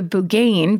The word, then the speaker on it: Buggane: My other favorite is the Bougaine, B-U-G-G-A-N-E, and then it says pronounced like it's spelled, I think, which is some kind of bad fairy, because you know how much Celtic people love fairies.